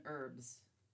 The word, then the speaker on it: herbs: When you pronounce the H in house and herd, but not in herbs, explain yourselves, America.